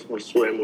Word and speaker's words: Josue: Yeah, my name is Josue Molina, and I'm a man. – Okay, can you spell your name? – J-O-S-U-E.